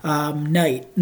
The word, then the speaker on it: knight: Knight as in like a knight with shining armor. I know it's confusing because it's spelled K-N-I-G-H-T and it sounds like knight also like an N, but really has a T in front. Sorry, it really has an N in front. I get it.